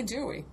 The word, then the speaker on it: DUI: I would say Dewey. – Okay, Dewey dialer. – Dewey. D-U-I. Yeah. D-W-I dialer.